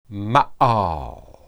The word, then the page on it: maal: hebrew